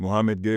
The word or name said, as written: Mouhamed Gueye